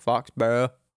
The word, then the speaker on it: Foxborough: Yeah, but you don't say Burrow when you're from Maine. It's Foxborough. – Foxborough. – Is yeah, Foxborough spelled with the U-G-H at the end of it? – Yes.